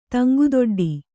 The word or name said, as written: ತಂಗುದೊಡ್ಡಿ